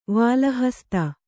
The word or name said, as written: ವಾಲಹಸ್ತ